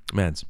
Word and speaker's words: Mads: Mads.